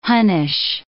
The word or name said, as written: Hunnish